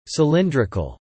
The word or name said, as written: cylindrical